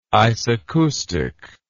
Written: isacoustic

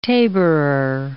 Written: tabourer

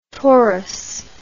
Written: torres

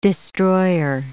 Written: destroyer

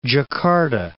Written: Djakarta

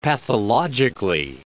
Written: pathologically

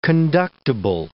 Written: conductible

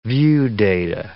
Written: viewdata

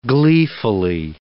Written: gleefully

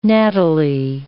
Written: nattily

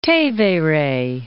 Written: Tevere

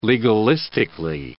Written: legalistically

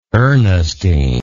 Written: ernestine

